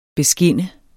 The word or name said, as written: beskinne